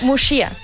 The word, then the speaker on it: Muxía: Es Muxía. – Muxía, vale. No, pero entendemos el error y Fer más que nadie entiende el error. – Sí, porque yo estuve mucho tiempo con lo de la Muxía esta cagándola.